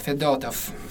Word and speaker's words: Fedotov: So that's right now the accent on the second letter, Fedotov.